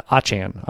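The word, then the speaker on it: Achane: He has Raheem Mostert and Devon Achan, I just saw the new pronunciation guide. Devon Achan. I'm practicing on the air here.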